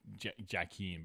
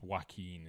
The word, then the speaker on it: Joaquin: But, well, it's just it's spelled like Jackeen, but yes, it's Joaquin is how it's pronounced.